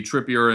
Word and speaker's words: Trippier: Son scored twice in three minutes uh, to take a 2 1 lead after Kyrian Tripper, Trippier? Tri- ah, man, pronunciation's not my strong suit today. Uh, but Kieran Trippier conceded a penalty uh, in the first 10 minutes of that match, which, which was not something that you wanted to see.